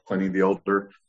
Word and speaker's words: Pliny the Elder: if you're going Pliny the Elder or Pliny the Elder, if you want to, you know, pronounce it properly, by Russian River.